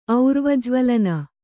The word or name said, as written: ಔರ್ವಜ್ವಳನ